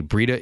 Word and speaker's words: Breida: Whenever healthy, Breida is unbelievable. – And just remember, it rhymes with cheetah.